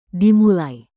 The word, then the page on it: dimulai: indonesian